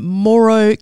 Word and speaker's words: Mooro: If you just Google that and look up plants and people in Moro country. So Moro is spelled M-O-O-R-O.